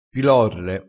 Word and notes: pilorre: dialettale

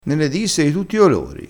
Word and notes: nene: dialettale

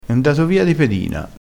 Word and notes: di pedina: dialettale

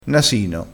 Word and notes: nasino: dialettale

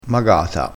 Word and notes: magata: dialettale

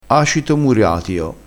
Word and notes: muriatio: dialettale

